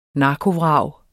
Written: narkovrag

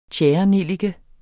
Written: tjærenellike

